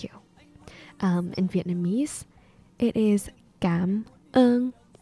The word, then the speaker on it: cảm ơn: Cảm ơn, C-A-M. Although it's a very soft C, it's not like a k, it's like a g. So cảm. And then ơn is O-N without the tone. So it sounds more of like a U.